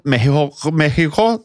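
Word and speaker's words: México: Må bare skyte inn at du nå bruker det, en variant av uttalen meksikanerne selv bruker om landet sitt. – Ja, det er riktig. – Så noen ville kalt det Mexico, men Mexico er...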